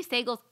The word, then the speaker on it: Sagal: Katie Sagal's amazing.